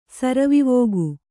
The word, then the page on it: ಸರವಿವೋಗು: Kannada